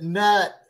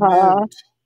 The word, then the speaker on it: Knut: It looks like you would pronounce it nut, KNUT, but it's newt.